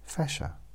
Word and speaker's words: Fascia: It's spelled Fascia, Fashia. But pronounced like Sasha or Tasha. So, Fasha.